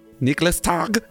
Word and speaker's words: Nikolaustag: It's the night before Nikolaustag. Nikolaustag. Is that right? – Yeah.